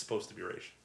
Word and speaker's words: Ra's: And, and, everything except the animated series, they always pronounce it Raj. – Yeah. – And in the show, in that show, it's Raish.